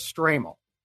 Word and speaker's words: Stramel: the pronunciation I found was Strammel. But anyway, Strammel,